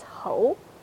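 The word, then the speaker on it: ho (猴): But there's another fun fact of monkey is in Chinese text, it's pronounced as ho. And this pronunciation is the same as high official.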